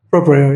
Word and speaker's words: Proprio: He's from Proprio. Am I pronouncing correctly? – Proprio, yep.